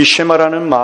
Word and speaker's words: 쉐마: 이 쉐마라는 말은 오늘 이 본문에 나오는 히브리어 원문의 첫 단어입니다. 쉐마라기보다도 쉐마 이렇게 발음하는 것이 좋은데요.